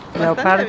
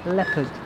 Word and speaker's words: leopard: Leopardo. – Bien, ¿eh? – But the correct pronunciation is leopard.